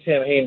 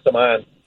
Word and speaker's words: Samhain: Called, Halloween is called Samhain, or it looks like Samhain, but in Gaelic it's pronounced Samhain.